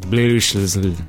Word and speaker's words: BLSHS: And last night I spoke with a group of fun people called Blushes, although they spell it B-L-S-H-S, so it's like Blushes, but they cleared that all up.